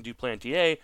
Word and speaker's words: Duplantier: First of all, let me commend you on the best pronunciation of Jean Duplantier yet so far that I've heard. So, why, thank you. French was my first language.